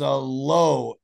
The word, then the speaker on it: l'eau: Water is low, L apostrophe E-A-U. And it's correctly pronounced en français, it's low.